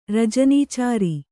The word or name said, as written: ರಜನೀಚರ